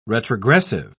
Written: retrogressive

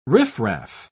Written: riffraff

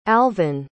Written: alvyn

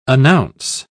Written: announce

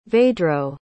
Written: vedro

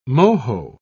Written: moho

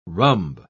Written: rhumb

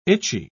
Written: itchy

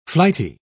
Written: flighty

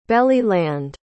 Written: belly-land

